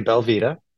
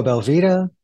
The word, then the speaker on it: belVita: So a Belvita, Belvita, Belvita? – Belvita. There's no L. – No, there's an L. There's clearly an L.